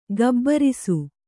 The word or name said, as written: ಘಾಬರಿಸು